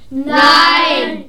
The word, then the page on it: nein: german